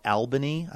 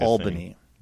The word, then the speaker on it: Albany: Albany, another place that I mispronounced for many years. I wanted to call it Albany. I don't know why. – It's a Utica thing. – But it's Albany.